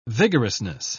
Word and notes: vigorousness: British English

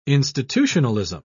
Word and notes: institutionalism: British English

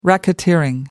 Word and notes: racketeering: American English

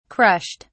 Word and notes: crushed: British English

